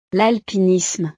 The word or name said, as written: l'alpinisme